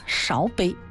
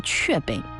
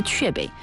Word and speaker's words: 雀碑: 我当时读成了勺。啊，勺杯。后来有咱们非常热心的听众朋友就提醒了，这叫雀碑，啊，是雀碑。